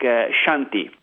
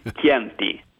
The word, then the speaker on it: Chianti: For example, in Latin America, it's even curious this kind of situation, because most of them before the academy used to pronounce Chianti like Shanti, like something that sounds more like French.